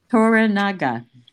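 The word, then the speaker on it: Toranaga: Torinaga. – Wow. – Did I pronounce it wrong? – No, no, you're just fine.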